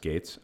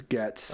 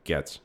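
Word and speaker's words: Goetz: G-O-E-T-Z. I think this is pronounced Gates. By the way, there's an unemployed... Yeah, or Getz. – G-O-E is Getz. – Okay, Getz.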